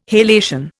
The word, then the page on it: halation: Indian